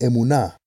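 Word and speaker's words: emunah (אמונה): In Hebrew, faith is pronounced emunah.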